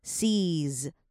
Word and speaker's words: sees: 好，那继续看下去，下一个要注意的重点就是 c 这个字了。c 后面，因为 Frankie 嘛，对不对？所以你后面一定要接一个 s， sees，最后面那个 s 是 z 的音， sees， sees。哎，其实好像美国也有一个蛮有名的巧克力品牌，也叫 sees，然后它就有出那个什么 butterscotch 的棒棒糖。其实大家如果去美国玩的话，也可以去买，还蛮好吃的。好，那来看到 plenty， plenty 呢，其实在这个句子里面，你有两种念法，你一个是你可以直接把 T-Y 那个 T-T-T 念出来，